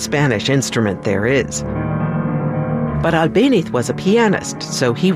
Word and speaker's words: Albéniz: The name Albéniz is spelled with a Z at the end. In Latin America, that Z is pronounced S, but in Spain, they say it with a TH sound.